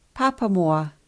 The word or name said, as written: Papamoa